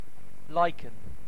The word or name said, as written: lichen